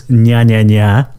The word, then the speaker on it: Njajaja: This is Nya Nya Nya, I think is maybe how you pronounce it, by smilelove. Smilelove, and I think it's Nya Nya Nya. It's spelled N-J-A-J-A-J-A, and obviously they're from Japan.